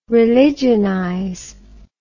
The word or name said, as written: religionize